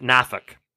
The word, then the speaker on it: Norfolk: Those that weren't born in the area will pronounce it Norfolk. Those who are from the area pronounce it Norfolk. And those who just wanted to get one over on the middle school teacher pronounce it Nafuk.